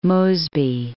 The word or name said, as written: Mosby